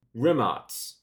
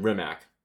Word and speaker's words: Rimac: The Rimac, and that's pronounced. Oh, it's Rimac, because it's Croatian rather than Rimac, whatever.